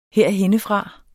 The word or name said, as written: herhennefra